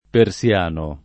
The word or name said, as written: persiano